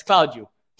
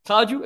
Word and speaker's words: Claudio: You're going to say it like you'd say it in Portuguese. And that's how I pronounce my name every day since. And it's like, it's Claudio. Claudio, if you can't say it, and okay.